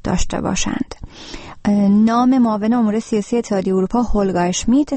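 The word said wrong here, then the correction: Helga Schmid: تلفظ شد که از شما معذرت میخوام و نام صحیح هلگا اشمیت است.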